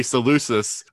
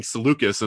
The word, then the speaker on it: Seleucus: I've always pronounced it Seleucus because it's a C. You know, it's a hard C. Apparently it's a hard C, and I didn't realize that. I've never heard it said. I've only ever read the name. And so I always want to say Seleucus, but they're saying Seleucus in the story, which is probably actually accurate.